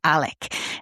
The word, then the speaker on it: Alex: Now, his name is spelled Alex, but, you know, in Southern Carolina, they pronounce it Alec.